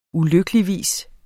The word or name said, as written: ulykkeligvis